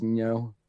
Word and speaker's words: Ngo: It's spelled NGO. He's I think it's Nyo.